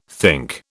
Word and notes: think: British English

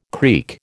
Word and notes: creak: American English

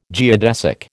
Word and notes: geodesic: American English

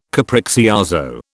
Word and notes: capriccioso: British English

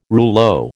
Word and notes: rouleau: American English

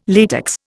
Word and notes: latex: Indian

